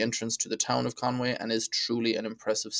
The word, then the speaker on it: Conwy: next up on the list of the Northwest Four is Conwy Castle, and that's Conwy without an A, which is also a weird pronunciation. I used to call it Conwy.